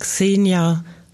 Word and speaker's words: Ksenija: Die heißt Xenia Bekeres. Etwas komplizierter ist es, ihren Vornamen zu schreiben, aber ähm, die Aussprache ist gar nicht so kompliziert. – K-S-E-N-I-J-A.